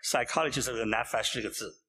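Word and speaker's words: nefesh (נפש): The Hebrew word behind it is nefesh, the word nefesh. – 犹太话里面这个字怎么念的呢？就是 nefesh.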